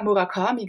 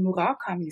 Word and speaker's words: Murakami: Und dort hat man mir dann gesagt, dass man eben Murakami sagt. Dass, dass alle das falsch aussprechen.